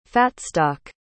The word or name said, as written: fatstock